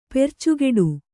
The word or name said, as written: ಪರ್ಚುಗುಡು